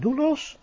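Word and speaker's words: doulos (δοῦλος): Je ziet dan dat de o, u van doulos als een u uitgesproken wordt.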